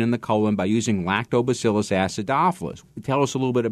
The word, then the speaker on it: Lactobacillus acidophilus: Lactobacillus acidophilus.